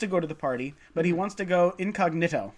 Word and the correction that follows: incognito: No, incognito. – Incognito, pardon incognito. – Me. Put the emphasis on the wrong syllable.